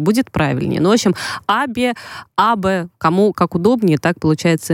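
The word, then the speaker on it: Абе (安倍): Абе, это в конце скорее русское «э» все-таки такое, знаете, абе. – Ну, конечно, интересно. – Абе будет правильное произношение, но, опять же говорю, я не хочу менять мир японистики.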